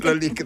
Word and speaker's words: Lalique: La Lalique dovrebbe pronunciarsi così.